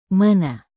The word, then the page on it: mana: Indonesian